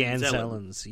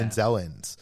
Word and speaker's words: Anzellans: Grief takes him to the Anzellians. Is that how you say it? – The Anzellans. Yeah. – Anzellans.